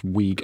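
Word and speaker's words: Wiig: How do you pronounce a double I? – Weeg? – Weeg? Weeg? – Yes. – Because the only other thing I can think of is Hawaii, so I suppose it's Weeg.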